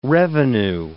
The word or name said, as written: revenue